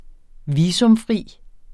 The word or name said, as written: visumfri